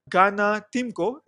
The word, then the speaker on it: Tymko: And with that, I would like to introduce to you my new guest, Ganna Tymko.